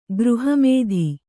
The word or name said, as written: ಗೃಹಮೇಧಿ